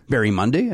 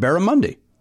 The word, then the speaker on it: Barramundi: Did I say? I didn't say Barry. Barry Monday. I said Barramundi.